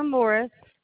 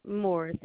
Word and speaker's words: Morris: call me back, Katina Morris. – Can you spell it for me? – Yes. CATINA. – And spell your last name. – Morris, MORRIS.